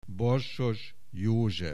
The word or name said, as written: Borsos József